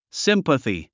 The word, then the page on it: sympathy: símpəθi; スィンパスィ